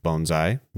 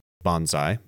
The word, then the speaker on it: bonsai: By the way, it's pronounced bonsai, not bonsai. Bonsai refers to a tree in a tray. Bonsai is a Japanese military maneuver from World War II.